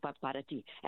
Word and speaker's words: para ti: I go, pot of tea, pot of tea. When I started saying that, then I knew the pronunciation correctly in Spanish, para ti.